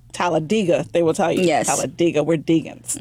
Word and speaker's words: Talladega: Actually, people in Alabama, it's actually Talladega. – Oh, yes. That's how you say it, Talladega.